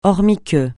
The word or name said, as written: hormis que